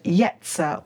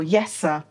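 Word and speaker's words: yetzer (יצר): The word here, mind, is the Hebrew word yetzer or yeser, Y-E-S-T-E-R. Sometimes it's spelt Y-E-S-E-R.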